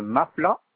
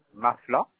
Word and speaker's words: Mafla: Monsieur Mafla Mohamed. – Ça s'écrit comment, s'il vous plaît? – Mafla, M-A-F-L-A.